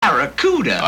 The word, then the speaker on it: Barracuda: Barracuda. – I can't pronounce Bacaruda. – Yeah, well, look, man, try this. Say back. – Back. – Ra. – Ra. – Coo. – Coo. – Da. – Da.